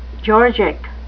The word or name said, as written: georgic